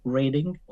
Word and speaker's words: Reading: Um, é uma cidade que se chama Reading, para, para, as pessoas que não estão habituadas a pronunciar este nome, podem confundir com o verbo reading, ler, é interessante, uh, mas é reading que se pronuncia, portanto fica a cerca de meia hora de comboio de Londres, portanto é onde eu estou a viver neste uhum.